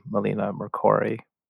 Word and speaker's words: Melina Mercouri: You're using the kind of the biography of the life story of the Greek actress and activist, Melina Mercouri. Did I say her name correctly? Okay. – Very well.